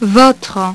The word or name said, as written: votre